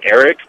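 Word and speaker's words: Aric: Yeah, my middle name is actually Eric, but it's spelled differently. It's A-R-I-C.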